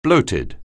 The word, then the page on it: bloated: anglais